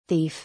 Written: Thief

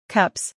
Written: Cups

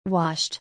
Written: Washed

